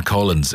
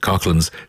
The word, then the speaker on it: Coughlans: You'll find out more at, and I will spell it for you because I would have pronounced it Coughlins, C-O-U-G-H. – No, that's what you do, brother. – C-O-U-G-H-L-A-N-S.ie.